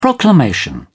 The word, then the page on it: proclamation: British English